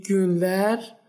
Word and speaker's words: günler: خب میشه اینو برامون بازش کنی این گون با یو هستش درسته با یو که دو نقطه بالاش داره پس اینو باید ما او تلفظ کنیم هم ای گونلر و هم گونایدن با او تلفظ میشه درسته درسته تلفظت خیلی درسته